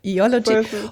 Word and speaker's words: Eology: Eology habe ich gelernt. Die Betonung liegt auf dem ersten O. Eology.